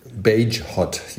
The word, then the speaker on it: Bagehot: Bagehot, ja.